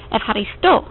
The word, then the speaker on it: Ευχαριστώ: Ευχαριστώ. Ευχαριστώ. If you find the hard H difficult to say in the middle of the word, you can use a K. Ευχαριστώ. Ευχαριστώ. Both pronunciations are understood.